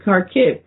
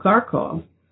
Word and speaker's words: Kharkiv: We don't hear about any of that, so I don't know, but she had a press conference in Kharkiv, what we used to call Kharkov, but now they spell it Kharkiv.